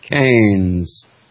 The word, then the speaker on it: Keynes: Lowercase k, long a, n, z. Now, for all of those of you out there that might be dictionary challenged, how do you pronounce the name? Keynes.